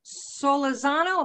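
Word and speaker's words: Solórzano: My guest today is Adriana Solarzano. Am I saying your name right? – That is correct. It's Adriana Solarzano. – Solarzano. – Right. – Gotta roll my R.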